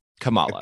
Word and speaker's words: Kamala: So whenever I've heard them speak about her and in the show, they say Kamala. – Okay. Kamala. – I believe, right? – I think so too. Yeah. – So it's pronounced not like the vice president.